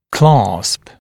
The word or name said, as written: clasp